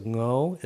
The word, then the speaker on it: Ngo: Because like, okay, so Ngo is a very hard pronunciation for me. – Is that how you say it? – Yeah, it's just like the sound with the N and the G in Vietnamese is specific. It's just hard.